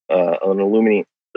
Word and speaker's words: aluminium: That's got 9 Merlin rockets that use an aluminum lithium alloy tanks. – Aluminium. Yeah, that's how he pronounces it.